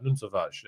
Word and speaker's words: Nuno Tavares: Nuno Tavares is how you pronounce it, and you'll find out how you properly pronounce it from João on the show.